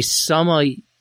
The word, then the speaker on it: sommelier: A sommelier is what exactly? – You nailed it.